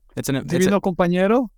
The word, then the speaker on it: Divino Compañero: So your work on, and you know, correct my pronunciation, I am not a native Spanish speaker, but Divino Compañero. – Divino Compañero? – Yes.